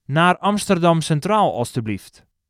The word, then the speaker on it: naar Amsterdam Centraal alstublieft: In Dutch, to Amsterdam Central Station please is naar Amsterdam Centraal alstublieft. Naar Amsterdam Centraal alstublieft. Let's break it down by syllable. Naar Amsterdam Centraal alstublieft.